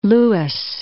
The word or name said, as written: Lewes